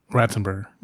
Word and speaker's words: Ratzenberger: I wasn't really looking for it because John Rathisberger, is that how you say his name? – Ratzenberger.